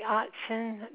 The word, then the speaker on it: Ottzen: Beverly Otzen. That's spelled O-T-T-Z-E-N.